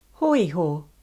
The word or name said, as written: Hoiho